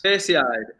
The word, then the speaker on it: Perseid: Persoid, how do you say this? – Perseid. It's a meteor shower.